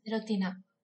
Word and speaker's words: serotina: Es una piña serótida. – ¿Lo he dicho bien? – Serotina. – Serotina, sí.